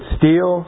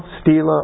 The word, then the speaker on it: Stela: By the way, it's Stele, Stela, or Stele, are all the right pronunciations for a singular stone that's been carved on.